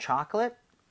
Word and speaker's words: chocolate: 자, C-H-I-P, 칩까지 넣어서 예? mint chocolate chip. 이건데 발음까지 끝내주게 해볼까요? – 네. – Alright. Chocolate 할 때는 앞에 O의 강세가 가죠. 그러면서 입 모양이 좀 벌려지겠죠. 예. So, 초콜릿보다는 초콜릿에 가깝고요. chip 할 때는 입 모양을 벌리면 안 됩니다. 그러면은 C-H-E-A-P, cheap.